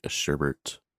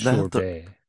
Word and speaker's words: sherbet: It's Billy which is Sherbet. – I was going to say, which is not the right way to pronounce the frozen dessert that is sherbet that everybody says wrong, and it drives me nuts.